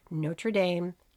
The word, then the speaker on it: Notre Dame: He was offered football scholarships from 75 different colleges, including legendary football powerhouses like Notre Dame, yeah, that's the American pronunciation, the University of Tennessee, and Boston College.